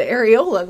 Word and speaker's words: Arreola: It says Areola, but A-R-R-E-O-L-A, Areola. – Mine were just out. – That's so weird. – Spell it again. – A-R-R-E-O-L-A, Areola.